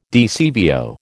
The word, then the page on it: DCVO: American English